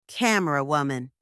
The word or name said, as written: camerawoman